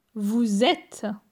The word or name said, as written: Vous etes